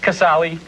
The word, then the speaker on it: Casale: Jerry, am I pronouncing that right? – That's fine. – How do you pronounce it? – Casale. – Okay. – It's Italian.